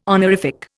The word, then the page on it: honorific: Indian